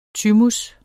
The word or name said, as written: thymus